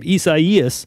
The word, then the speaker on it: Isaias: All right, we should pray for the people that were impacted by hurricane or tropical storm Isaias. – How do you say that? I'm going to do this phonetically here. – Isaias. – Isaias. – Mm-hmm. – Isaias? – Mm-hmm. Correct. – Okay. E-S-I-E-S.